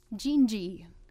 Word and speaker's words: Dindi: D-I-N-D-I. Yeah, so a little surprising on the pronunciation again. – Well, that's the thing about Portuguese. – Yeah, a lot of the consonants soften. So C-H is pronounced sh, like an S-H.